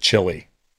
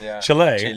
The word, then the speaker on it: Chile: How long did it take you living in the United States where you stopped getting annoyed that Americans called it chili? – What do you say? – Chili. You know, Americans say chili. They don't say Chile.